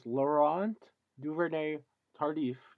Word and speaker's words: Laurent Duvernay-Tardif: But I'm sure Andy Reid wishes Laurent Duvernay-Tardif, yes, I got it. I said it right with the right accent.